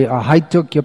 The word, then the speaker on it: ahaitukya: Savai Pung Sang Paro Dharmo Yato Bhakti Radhokshaje Well, you left out the ahaitukya. There should be ya, should be pronounced. Not the I, it's not pronounced as I, but there is, it's pronounced.